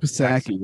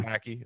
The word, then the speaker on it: Psaki: It's like Psaki, something like that. – Psaki, yeah. – Psaki, it's like Helga Pataki.